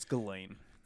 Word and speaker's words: Ghislaine: I think it's Ghislaine. – Ghislaine. – I think the... – Ghislaine. – Yeah, I think the S is silent.